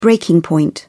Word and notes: breaking point: British English

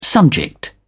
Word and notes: subject: British English

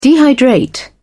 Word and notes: dehydrate: British English

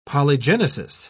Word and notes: polygenesis: British English

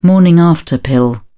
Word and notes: morning-after pill: British English

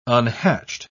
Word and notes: unhatched: British English

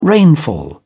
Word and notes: rainfall: British English

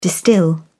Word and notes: distil: British English

